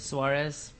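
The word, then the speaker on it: Suárez: Have y'all heard about Luis Suarez? – Suarez. – Mm-hmm. That's how you pronounce it.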